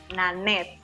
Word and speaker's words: Nanette: Hay otro que también se llama Nanette, ¿lo estoy pronunciando bien? – Nanette, sí lo estás pronunciando bien.